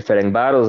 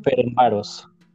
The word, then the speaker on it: Ferencváros: Ayúdame con esa pronunciación. – El Ferenbaros de Hungría, por favor. – Ferenbaros.